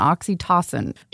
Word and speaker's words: oxytocin: Oxytocin.